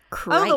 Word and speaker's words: krait: It's spelled K-R-A-I-T. – Oh, K-R-A? – Yes, but it's pronounced Krite.